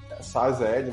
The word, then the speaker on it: SASL: Então quando eu falo de autenticação, existem N formas de você autenticar no CAPTCHA, usuário, MTLS, SSL, etc. Uh, ah, SASL, na verdade, né, não é SSL, falei errado, SASL.